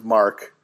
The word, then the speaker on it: marque: No, for letters of marque. – Oh, did I say that wrong, too? – It's letters of marque.